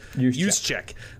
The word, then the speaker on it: Juszczyk: I think creative offensive coordinators have found ways to use guys like Kyle Juszczyk, or Juszczyk, how do you say his name, from San Francisco? – Juszczyk.